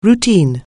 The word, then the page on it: routine: anglais